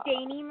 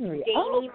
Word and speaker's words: Dani: This is Dani Marie, and I'm calling from Los Angeles, California.